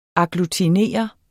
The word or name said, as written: AGGLUTINERE